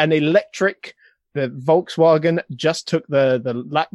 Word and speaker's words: Volkswagen: v- Volkswagen.